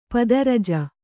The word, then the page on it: ಪದರಜ: pada raja